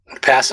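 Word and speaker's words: pā: Pass him. – Pass. – Pa. And it's P A with a line over it, and they say pronounced pa. So I appreciate the pronunciation.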